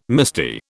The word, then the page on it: misty: British English